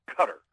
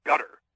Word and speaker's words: Qatar: Yes, and don't forget Al Gore's sale of his current TV cable network to Al Jazeera and the Emir of Qatar, which, by the way, is spelled Q-A-T-A-R, but it's pronounced as Qatar or gutter, not Qatar.